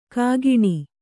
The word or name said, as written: ಘಕ್ಕನೆ